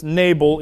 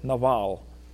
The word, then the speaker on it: Nabal: We pronounce this Nabal in English because we tend to put our emphasis on the first syllable, but Hebrew doesn't have a long A sound. So it's Nabal is his name, Nabal.